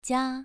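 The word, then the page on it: jia: tone 2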